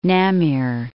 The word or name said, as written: Namier